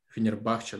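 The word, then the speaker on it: Fenerbahçe: И я так понимаю, что ближе всего Дзюба реально к турецкой суперлиге, Фенербахче, Фенербахче, да, правильное ударение, вот.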